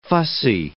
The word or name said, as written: fussy